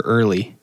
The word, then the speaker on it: Earle: Is it Earl or Early? – Is it, how do you spell it? – E-A-R-L-E. – So I'd say Earl.